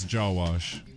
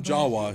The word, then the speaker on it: Jawash: It's two syllables. It's jaw wash. It's really easy to pronounce. – Okay. Jaw wash. Let's try it again. Yeah. – That's easier than my last name. Adam Jawash.